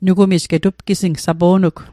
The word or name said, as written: getu'pgising